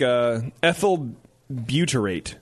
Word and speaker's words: ethyl butyrate: So, and then you have stuff like ethyl butyrate.